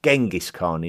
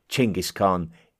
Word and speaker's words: Genghis Khan: It is suggested that an influential shaman made the great proclamation that Temujin would be referred to as Genghis Khan, which is something that has been more commonly pronounced Genghis Khan in the Western world, until more recently when a closer approximation to the correct local pronunciation, Genghis Khan, is starting to be preferred.